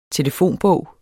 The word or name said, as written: telefonbog